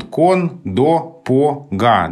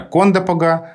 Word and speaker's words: Кондопога: В общем, так это и осталось, получается, что местный вариант – это Кондопога, вариант широко употребительный в литературном языке – это Кондопога.